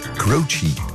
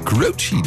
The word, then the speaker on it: Croce: Maar eigenlijk heb ik gehoord dat je dat moet uitspreken als Croce. – Ja. – Dat wist ik helemaal niet. Ik zei altijd Jim Croce, maar het is Croce.